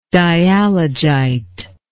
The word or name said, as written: dialogite